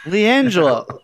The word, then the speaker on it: LiAngelo: Jello, is that how they pronounce his name? LiAngelo? LiAngelo? – LiAngelo.